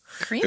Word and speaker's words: Kremo: Cremo. – It's spelled K-R-E-M-O.